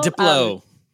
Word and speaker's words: Diplo: Then Diplo, I don't know how to pronounce Diplo. I guess Diplo. – Diplo. – Diplo. There you go. I needed someone to say it.